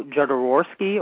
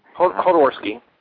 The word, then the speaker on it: Jodorowsky: Alejandro Jodorowsky or Hodorowsky? – Hodorowsky.